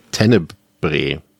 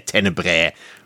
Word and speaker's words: Tenebre: wenn Deutsche den Filmtitel aussprechen, klingt das immer irgendwie falsch. Ähm, vielleicht ist es aber auch, wenn alle den aussprechen, irgendwie falsch. Es ist äh, Tenebre.